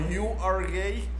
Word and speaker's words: Uruguay: en inglés Uruguay se pronuncia como you are gay.